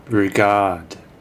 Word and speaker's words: Rugaard: He's played by Carla Rugaard. Clara Rugaard. That's what I said. There's two A's, so you have to really lean on the second syllable.